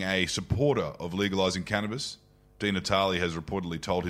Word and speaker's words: Di Natale: Well, Senator Di Natale, thank you for joining us today.